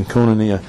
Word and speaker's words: koinonia: Okay, the word fellowship there is the Greek word koinonia, or koinonia. I'll just stick with my previous pronunciation, koinonia.